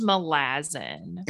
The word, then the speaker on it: Malazan: It's pronounced Malazan. – Whatever. Malazan. Okay.